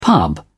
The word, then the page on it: pub: British English